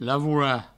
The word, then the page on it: lavorar: lavoura; Provençal